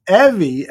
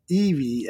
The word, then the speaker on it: Evie: It's Evie as in Chevy, not Evie as in Dummy Aldo.